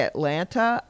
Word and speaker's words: Atlanta: I have to, when I'm talking to someone and I say Atlanta, I make sure to say the last T, even though that is not how it is pronounced in the city.